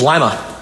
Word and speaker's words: Lima: And remember, it's not said like the city in Peru. – What is Lima? – Yes.